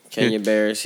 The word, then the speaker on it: Kenya Barris: Kenya Barris. Did I say it right? Barris? – Yeah. Kenya Barris.